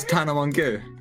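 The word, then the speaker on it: Tana Mongeau: Sorry, can you just say her name again? – Tana Montague. – You know it's Tana Mongo. – No, it's not.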